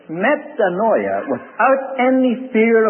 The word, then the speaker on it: metanoia: The Greek word that's translated repentance is metanoia. I'll spell it in English. M-E-T-A-N-O-I-A.